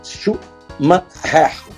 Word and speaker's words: Schumacher: É como se fosse separação de sílaba. S-C-U, chu. Chup. Ma, separado. Her, H-E. R é her. Então é Schumacher.